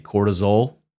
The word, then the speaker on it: cortisol: Cortisol. That's how you say it, right? – Yes.